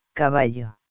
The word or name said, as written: caballo